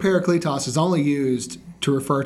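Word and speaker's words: parakletos (παράκλητος): One of my favorites is something I learned probably in the last few years, and it's a Greek word that's pronounced parakletos. And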